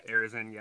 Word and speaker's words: Arizin: Paul Arizin, I can't remember how to say his name. – Arizin, yep.